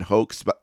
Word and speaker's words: Hoch: The author is the very kind Bryan Hoch, rhymes with Coke. You can find him at Twitter at Bryan with a Y, H-O-C-H.